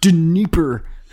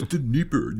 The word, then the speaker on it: Dnieper: You mispronounced that guy's name. – It's Dnieper. – It's Dnieper, you idiot.